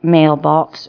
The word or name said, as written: mailbox